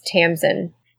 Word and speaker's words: Tamsyn: Mary, by the way, I was looking at this while you're reading, but I think it's Tamsin.